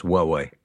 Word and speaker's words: Huawei: That company you mentioned earlier, that Huawei. Huawei. How are we? – No, it's pronounced Huawei. – Huawei. With a H.